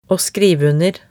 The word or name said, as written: å skrive under